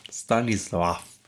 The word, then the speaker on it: Stanisław: Stanisław.